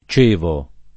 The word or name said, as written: Cevo